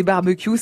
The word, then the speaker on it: barbecue: Si j'ouvre le grand Robert, on propose barbecue ou barbecue. Mais si je consulte le dictionnaire de l'Académie française, une seule prononciation est proposée. Mhm. u CUE, est-il écrit, se prononce Q.